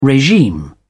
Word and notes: regime: British English

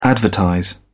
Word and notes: advertise: British English

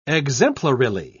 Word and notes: exemplarily: British English

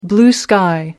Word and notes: blue sky: American English

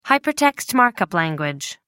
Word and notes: hypertext markup language: American English

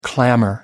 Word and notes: clamor: American English

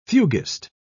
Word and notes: fuguist: British English

